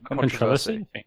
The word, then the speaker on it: controversy: Controversy. – I think if you're American, it's controversy.